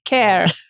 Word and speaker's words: Kerr: Kerr. – It's spelled K-E-R-R, but it is because you come from Ireland.